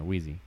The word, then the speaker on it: Wheezy: How sophisticated is Squeaky, or am I saying his name right? From on Slinky Dog. – Oh yeah, he's Wheezy.